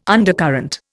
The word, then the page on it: undercurrent: Indian